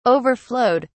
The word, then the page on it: overflowed: British English